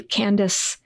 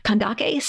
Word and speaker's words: Kandake: Yes, yeah, spelled with a C, and it looks like it should be pronounced with a soft C, Candace. You know, it's become a woman's name. Mm-hmm. And if you go back to our sources, it's spelled with a kappa, so we know that it was pronounced with a hard K sound, Kandake.